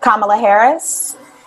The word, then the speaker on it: Kamala Harris: Congrats to the vice president nominee elect Kamala Harris.